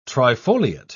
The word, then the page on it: trifoliate: British English